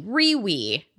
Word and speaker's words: REWE: It's spelled R-E-W-E, Riwi Virtual Eatery.